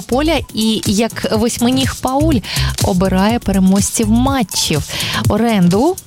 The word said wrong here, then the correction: Оранду: власне, ні, Оранду її звуть, я думаю, не вже таке прізвисько.